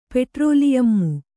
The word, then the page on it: ಪೆಟ್ರೋಲಿಯಮ್ಮು: petrōliyammu